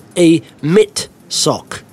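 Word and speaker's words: Amitsoq: and I didn't know how to pronounce Amasoc. And basically, what it's like, you say, you pronounce it like a mitt sock.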